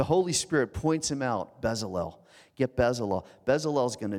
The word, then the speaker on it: Bezalel: Everybody say Bezalel with me so I don't feel bad. Bezalel, good.